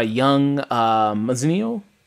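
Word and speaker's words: Young Mazino: And his brother Paul is played by Young Mazzino, is how you say his last name.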